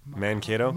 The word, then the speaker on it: Mankato: Now, I also, I also find it fascinating that you moved from, Makata, how do you pronounce that? – Mankato? – Makata. I can't read my own handwriting. Mankato, Minnesota.